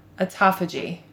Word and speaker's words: autophagy: autophagy. A-U-T-O-P-H-A-G-Y. – That's not how you pronounce it. – How do you pronounce it? – Autophagy. – Autophagy. – Mm-hmm. – Okay. – But it's spelled auto.